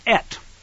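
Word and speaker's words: et (את): The Aleph Tav is pronounced, and the word is et in Hebrew.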